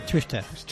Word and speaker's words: Schuester: un tal Will Schuster o algo así, porque no sé pronunciar esto. – A ver, ¿cómo, lo has, pronunciado? Will Schuester. Ah, Schuester. – Schuester, como Schuster en Netflix, en Netflix eh, me pues están diciendo que, que la echan.